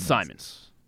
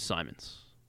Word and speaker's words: Simons: Simons. Yeah, I thought it was Simmons as well, but it is Simons. – Are you sure? – Anthony? Yes, it's S-I-M-O-N-S. – But that doesn't mean it's not pronounced Simmons. – I'm almost positive it's Simons.